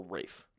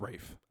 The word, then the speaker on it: Ralph: Also, is it Ralph or Rafe? – I heard it's Rafe. – I think it's Rafe. – Yeah. – But it's spelled Ralph. – It is. – All right. – It's, R-A-L-P-H, but I guess he goes by Ray.